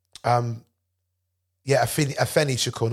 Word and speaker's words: Afeni Shakur: It's Athena Shakur, I think it's pronounced. Just want to make sure I pronounce that correctly. Afeni Shakur. Not Athena, sorry.